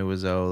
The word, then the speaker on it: Wiseau: I think I've heard it pronounced Wiseau.